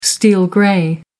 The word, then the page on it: steel grey: American English